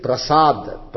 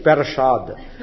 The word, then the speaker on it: prasad: Like prasad. Prasad is pa and refa. They make it parashad. And they write it also as parashad. P-E-R like. Not P-R-A. P-R-A is what it is. They write P-E-R.